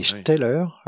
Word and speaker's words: Steller: Oui, Bering embarquait un, naturaliste qui s'appelait Steller, et Steller a été l'un de ceux à l'époque qui, le premier, a émis cette hypothèse. – Oui, alors tu l'appelles Steller, c'est bien, tu prononces bien les mots allemands.